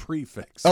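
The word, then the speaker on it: prix fixe: Yeah, Juan's family was in town, and we went to a Prix Fix, which, you know, is where all the courses are laid out. – Prefix. – Oh, prefix, sorry. Well, it's spelled Prix Fix.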